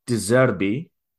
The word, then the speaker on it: De Zerbi: Roberto De Zerbi.